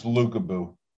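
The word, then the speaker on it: Lukabu: I don't know if it's Lukebu or Lukebu. I haven't looked into the pronunciation. – It's Lukebu. I heard him say his name.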